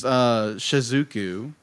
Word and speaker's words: Shizuku: So, it's Shizuku. Am I saying that right? – Yes.